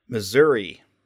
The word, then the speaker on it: Missouri: Missouri. – You say with a E sound at the end. – Yes, because there's an I at the end, and it's not an A, so...